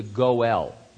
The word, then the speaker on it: Goel: This person was called in Hebrew the Goel. G-O-E-L is how we would spell it.